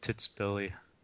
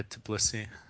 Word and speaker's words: Tbilisi: It's not Titsbili or however you pronounce that. – Tbilisi? – Yeah, Tbilisi.